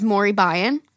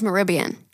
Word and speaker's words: Moribyan: I was saying her name is Mori Byan, because her at on Instagram is M-O-R-I-B-Y-A-N. But that's her name. Her name is Maribyan. which is so fucking pretty.